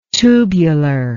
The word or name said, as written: tubulure